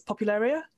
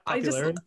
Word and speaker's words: Popularia: So like, I will never say it right. It's a problem. I always say it wrong at the last minute, but it's Popularia. Is that the one? – Yeah, that's right.